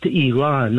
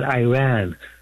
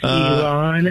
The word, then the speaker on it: Iran: I was there, and then guest of yours from Idaho, it's pronounced Iran, not Iran. Like, we don't say Italy, we say Italia. – Well, some people say Italians sometimes. – It's Iran, and in the 1930s, the English name went from Persia to Iran.